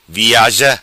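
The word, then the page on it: Viaza: patua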